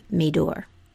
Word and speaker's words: Meador: Meador. That's spelled M-E-A-D-O-R.